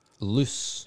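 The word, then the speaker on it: Luce: Loose. – L-U-C-E, I think is how you say it.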